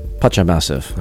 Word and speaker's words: Pacha Massive: And Pasha Massive. Am I saying it right? – Pacha Massive.